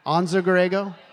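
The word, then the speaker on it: Anza-Borrego: It's not Bonzo Gorego? Anza Gorego? Okay.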